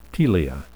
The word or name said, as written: telia